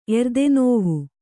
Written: ಎರ್ದೆನೋವು

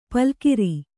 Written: ಪಲುಗಿರಿ

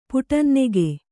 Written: ಪುಟಂನೆಗೆ

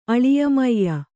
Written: ಅಳಿಯಮೈಯ

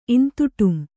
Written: ಇಂತುಟುಂ

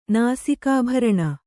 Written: ನಾಸಿಕಾಭರಣ

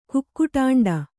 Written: ಕುಕ್ಕುಟಾಂಡ

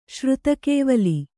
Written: ಶ್ರುತಕೇವಲಿ

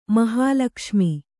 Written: ಮಹಾಲಕ್ಷ್ಮಿ

